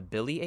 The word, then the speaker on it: Bili: So they're also known as the, I believe it's pronounced the Bili Apes. It's spelled B-I-L-I.